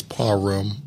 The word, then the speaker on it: Parham: The hood say Parham. The the correct way is parum.